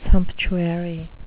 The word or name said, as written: sumptuary